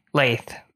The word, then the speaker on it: Laith: We also have Alejandro or Alex from Instagram, and Laith is L E P, Laith, rhymes with faith.